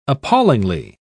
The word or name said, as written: appallingly